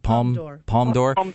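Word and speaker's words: Palme d'Or: Is it Palm? How do you pronounce that? – Palm d'Or, which is the grand prize.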